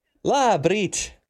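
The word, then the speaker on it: Labrīt: How do you say good morning in Latvian? Is it Labrit, if I remember? – Yes, it is. – Labrit.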